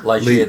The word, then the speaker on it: Ligeia: And I can never pronounce this, but the Tomb of Ligeia? – Ligeia, they pronounce it.